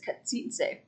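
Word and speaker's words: Čachtice: There's different accounts on how to pronounce it. I believe the most common is Katsitze. – Katsitze.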